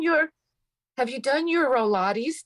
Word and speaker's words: Rolates: Info and Rolates, R O L A T E S dot com. Rolates to rhyme with Pilates.